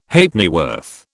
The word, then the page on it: Halfpennyworth: British English